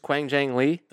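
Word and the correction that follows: Hwang Jang Lee: Huang Jang Lee. – I've been saying Quang for like 3 years. – Oh, good. That's fine. It's Huang. – Yeah, it's Huang.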